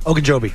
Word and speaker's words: Ogunjobi: Is it Ogunjobi? – Ogunjobi.